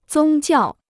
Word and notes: 宗教: zōng jiào

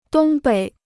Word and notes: 东北: dōng běi

